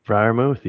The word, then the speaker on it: Freiermuth: Freiermuth, yeah.